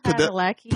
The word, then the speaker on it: Padalecki: How do you say his last name? – Padalecki. You're welcome.